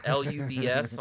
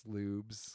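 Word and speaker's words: Lübs: It's not Lubs, it's Lubes. – It's Lubes. – You pronounce the he's got an he's got a new, I think it's L U with an umlaut.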